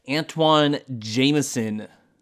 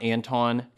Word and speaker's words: Antoine Jamison: Antoine Jamison. For those of you who pronounce the name wrong, it is not Anton, kind of like Dwayne Wade. He just spells it weird.